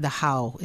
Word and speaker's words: Dachau: We went to Dachau, or I, I think I'm saying it, Dachau is the re- proper pronunciation, Dachau concentration camp.